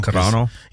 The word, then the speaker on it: Carano: Ronda Rousey, Gina Carano. And I'm saying her last name wrong. – Carano.